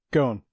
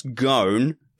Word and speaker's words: Ghosn: Ghosn. – Yeah. Carlos Ghosn Tell me what was happening.